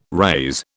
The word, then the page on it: raze: British English